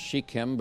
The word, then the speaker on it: Shechem: From time to time, you will hear the word Shechem and you'll hear the word Shechem. The actual correct pronunciation is Shechem, but for someone who was raised all their life in Latin culture, or E's or A, so it's Shechem for me, but it's really Shechem for you.